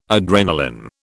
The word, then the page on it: adrenalin: British English